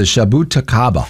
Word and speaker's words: jabuticaba: I had to look up how to pronounce this one, too, because it's the shabutakaba. – God bless you. – J-A-B-U-T-I-C-A-B-A.